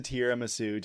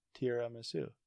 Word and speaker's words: tiramisu: Why'd you say it like that? – What? – Tiramisu. – Tiramisu? – Tiramisu. – Tiramisu. – Yeah, but you're choppy reading over there. – Tiramisu? Am I saying that wrong? – Yes. Tiramisu. – Tira. – Tiramisu. – Tiramisu. – See, that's how I was going to say it. I would say tiramisu.